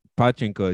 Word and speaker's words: pachinko: So, the other thing about pachinko is you have to pronounce it correctly. – Pachinko. – Yeah, because if you want to stress the first or the last sounds, you don't want to stress the middle one, because in Japanese that sounds dirty.